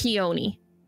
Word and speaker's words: Chione: Before you ask, it is Chione, but it's pronounced Hioni. – The ch is silent.